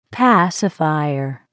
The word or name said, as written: pacifier